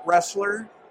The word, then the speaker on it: Roessler: Well, yeah, if I go down south, I'll say cattle Roessler, and then the...